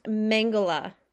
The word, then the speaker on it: Mengele: Okay, so his name is then pronounced Mengele.